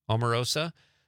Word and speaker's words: Omarosa: Steve from Florida, Conrad's dad checking in, says Amorosa. I think I'm pronouncing that right. Omarosa, because she reminded me of the worst boss I experienced in the 90s.